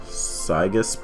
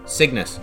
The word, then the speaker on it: Cygnus: Cygus, probably. – Lacey? The spelling is close to Chungus. – Cygnus. – Cygnus? – Yeah. – Sigma. – Oh, I didn't see the N. I'm kind of... – Cygnus, like the transport spacecraft.